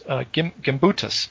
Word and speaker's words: Gimbutas: Old Europe is a term coined by archaeologist How do you pronounce her name again? Maria Galumbas, is that it? Here it is. Gimbutas, Maria Gimbutas.